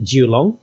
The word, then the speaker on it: Kowloon: the area is called kowloon and uh, that in cantonese you actually pronounce it jiulong which um, which means nine dragons so you look at the the main hills, and there's basically nine hills in a range.